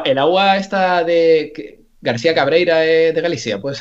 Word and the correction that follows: Cabreiroa: Cabreiroa, sí.